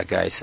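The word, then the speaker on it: Guice: Yeah, I'm glad you mentioned Geis because I think you're pronouncing Grice. But, yeah, Geis.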